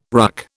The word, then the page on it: RUC: British English